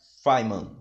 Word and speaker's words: Feynman: Enfim, Freeman. É, se você pegar Feynman, Feynman, é.